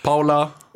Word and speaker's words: Paul: Alltså i Skåne, om man stavar P-A-U-L så är det ju Paul.